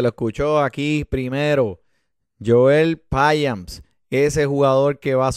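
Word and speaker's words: Payamps: mira, pero detrás del hombre tienen a Joel Piamps. Así que se pronuncia, Piamps.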